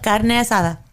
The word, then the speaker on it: carne asada: So I want to get back and talk to you about carne asada. Am I saying it right? – Yep, carne asada.